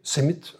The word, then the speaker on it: CIMMYT: C-I-M-M, Griekse I-T, CIMIT uitgesproken,